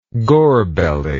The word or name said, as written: gorbelly